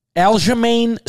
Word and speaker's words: Aljamain: Card is absolutely electric this week with Sean O'Malley and how do we say this other guy's name? – Aljamain Sterling, cousin. This guy I just say Sterling, right? – This guy's a fucking fighter.